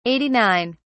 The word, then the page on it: 89: British English